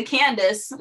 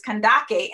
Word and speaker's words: Kandake: Okay, we say Candace, which is incorrect, but the actual pronunciation is Kandake, and that means queen.